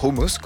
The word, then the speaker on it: houmous: C'est le cas du houmous, ou houmous, comme il faut le prononcer, point épineux, au point qu'il existe une guerre du houmous.